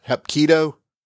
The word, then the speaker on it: Hapkido: You said you also had a black belt in, I'm going to mispronounce, Hapkido? – That's right.